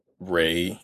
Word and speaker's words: Rae Sremmurd: They are Rae Sremmurd, Is that, that's how you pronounce their the name? – What? Ray.